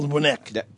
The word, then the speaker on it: Wnek: It looks like Pavel, a Polish name, W-N-E-K. It's Paul. – Especially. – Paul Winnick. – Paul is P-A-U-L, not P-A-W-E-L.